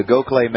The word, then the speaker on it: Gokhale: And I have linked up in the show notes the Gokhale Method. It's spelled differently than it sounds. It's G-O-K-H-A-L-E.